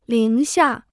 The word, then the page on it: 零下: líng xià